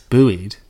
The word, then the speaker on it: buoy: Buoyed by life vests, they swam. – Buoyed. How is it spelled? B-U-O-Y. – Americans say buoy, we say buoy.